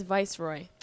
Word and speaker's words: Viceroy: Is that how you say it? I always said Viceroy. It's Viceroy.